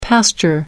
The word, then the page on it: pasture: American English